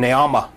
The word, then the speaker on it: Naamah: Now, it mentions here the sister of Tubal Cain was Naamah, close to Naomi almost, in pronunciation Naamah.